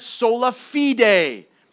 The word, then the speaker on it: sola fide: Today we're looking at sola fide, is how you pronounce it.